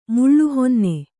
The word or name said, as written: ಮಲಹೊನ್ನೆ